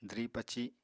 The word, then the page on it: dwri-paci: apatani